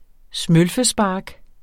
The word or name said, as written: smølfespark